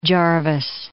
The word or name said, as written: Jervis Bay